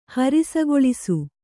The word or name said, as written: ಹರಿಸಗೊಳಿಸು